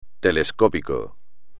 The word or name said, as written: telescópico